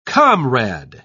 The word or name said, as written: comrade